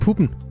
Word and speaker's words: Poppen: Poppen i Aarhus. – Kan ja, du lige sætte lidt ord på? Jeg vil gerne starte med at sige, at du skal udtale det puppen.